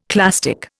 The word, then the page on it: clastic: Indian